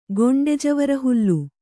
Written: ಗೊಂಡೆಜವರಹುಲ್ಲು

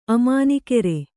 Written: ಅಮಾನಿಕೆರೆ

